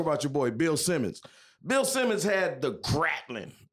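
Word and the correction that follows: Grantland: The Gratlin? Is that right? – He had Grantlin. – The Grantlin, okay.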